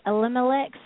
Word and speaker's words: Elimelech's: He offered him for the first chance to buy I never can say Elimelech's.